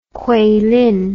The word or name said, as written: kueilin